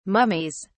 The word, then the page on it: mummies: British English